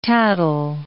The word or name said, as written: tattle